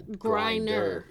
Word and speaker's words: Griner: Oh, yeah, Griner, like you grinding something.